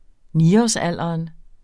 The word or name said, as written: niårsalderen